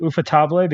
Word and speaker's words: Ufotable: But nonetheless, I think that how do you say it? Ufotable. It's spelled UFO table. – Yeah, but I have always called it Ufotable because one person told me it was Ufotable when I asked them.